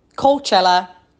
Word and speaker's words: Coachella: It's pronounced Coachella. Coach, as in a coach, in which you ride on. And Ella, as in our Lord and Saviour, former X Factor contestant, Ella Henderson.